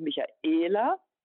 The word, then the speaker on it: Michaela: Denken Sie an Michaela da ist der Akzent hinten, haben sehr oft ein A oder ein E oder einfach einen Vokal hinten und sind länger als junge Namen.